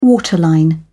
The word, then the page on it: waterline: British English